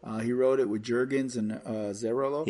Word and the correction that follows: Azzarello: Azzarello, I believe.